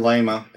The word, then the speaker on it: Thelema: It weaves this haunting account of the real-life occultist and founder of the I'm going to mispronounce this, Thelemite religion, which was Aleister. – Thelema. – Thelema, whatever.